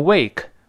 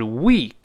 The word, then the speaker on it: week: 第一个是 for the week，不要再读成 wake，不是 wake，是 week，它把长音 e 这声音注意啊。